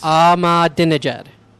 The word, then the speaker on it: Ahmadinejad: Ahmadinejad.